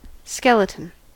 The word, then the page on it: skeleton: American English